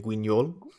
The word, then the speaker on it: Guignol: How do you pronounce this? – I can't. Guignol, I think. Guignol. – Guignol. – Yeah, sorry, I always use a Spanish pronunciation. Guignol.